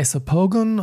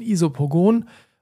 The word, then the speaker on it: Isopogon: Die zweite Essenz, die drin ist, heißt äh, Isopogon oder deutsch ausgesprochen Isopogon.